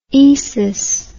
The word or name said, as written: esis